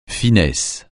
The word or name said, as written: finesse